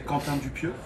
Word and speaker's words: Quentin Dupieux: Quentin Dupieux. – Are we getting it right? Ready? Quentin Dupieux. – In France, we say Quentin Dupieux. – Oh, Quentin Dupieux.